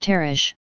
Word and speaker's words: Tarshish: So Jonah decides that he is going to run away to Tarshish, pronounced Tarish, which is in the absolute opposite direction.